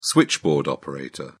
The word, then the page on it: switchboard operator: British English